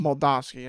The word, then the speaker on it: Moldavsky: Moldavsky, that's it.